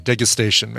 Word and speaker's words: degustation: It's a hard G, degustation menu.